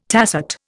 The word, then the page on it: tacet: Indian